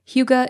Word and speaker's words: hygge: It is spelled H-Y-G-G-E, but pronounced hygge.